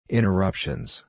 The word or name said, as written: interruptions